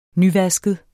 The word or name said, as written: nyvasket